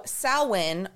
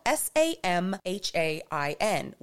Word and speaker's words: Samhain: And if you've never seen how Samhain is spelt, you would not even know that the word is Samhain because it is spelled S A M H A I N, which looks like Sam Hain.